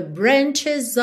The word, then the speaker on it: branches: I felt the branches of it looking at me. Branches, você pode pronunciar o E aí, ok? Para fazer esse plural.